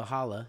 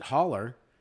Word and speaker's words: holla: That's hall weird. Is like the hallway, H-A-L-L, holla, you know what I'm saying? – Yeah. – But you pronounce it holler. It's like, hey, man, I'm going to holler at you, like, you know.